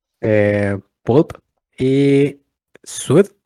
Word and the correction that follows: Suede: o Suede, no, aquí me corriges la pronunciación. Suede.